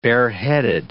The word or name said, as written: bareheaded